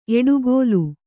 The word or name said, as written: ಎಡೆಗೋಲ್